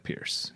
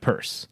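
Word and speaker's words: Peirce: A listener said commented on the website that it's not spelled like Pierce because it's not pronounced like Pierce. It's pronounced like Purse.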